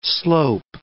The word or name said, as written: slope